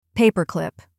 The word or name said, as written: paperclip